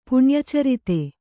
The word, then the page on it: ಪುಣ್ಯಚರಿತೆ: puṇya carite